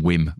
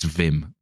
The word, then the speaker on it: Wim: He insists on calling him Wim, but it's Dutch, so it's Wim.